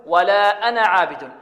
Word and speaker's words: أنا: تجدون ألفا بعد النون، لكن هذه الألف في حالة الوصل هل ننطقها في هذه الحالة؟ لا ننطقها، بل نحذفها، نقول ولا أنا عابد، ولا أنا عابد.